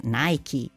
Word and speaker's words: Nike: Il significato è chiaro, ma vale la pena sottolinearne la pronuncia, è scritta con la E finale, I in inglese, che viene quindi scandita, proprio come il marchio Nike, che in inglese va pronunciato Nike, con buona pace della dea greca Nike e di chi, anche in Italia, legge appunto Nike.